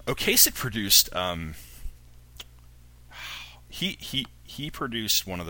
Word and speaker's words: Ocasek: Oh, and it was produced by, I'm not going to pronounce his last name correctly, Rick Ocasek of The Cars. – I believe that's correct.